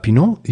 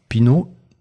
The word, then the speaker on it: Pinot: Pinot, mit der französischen Aussprache. Pinot.